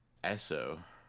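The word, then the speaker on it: Eso: How would you say it? – Esso. – Esso, thank you.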